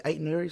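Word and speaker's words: Aït-Nouri: Is it Aitnouri, is that how you say it? – Yeah.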